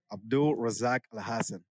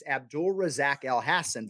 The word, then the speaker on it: Abdul Razak Al-Hassan: Because it seems simple on the surface, Abdul Razak Al-Hassan, but there are a lot of opportunities to wrongly emphasize a syllable.